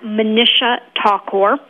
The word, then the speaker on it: Manisha Thakor: It's at Manisha Thakor.